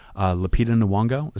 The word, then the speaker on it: Lupita Nyong'o: And I was talking to my manager about it, and she says, Lupita Nyong'o, is that how you say her name? – Yep.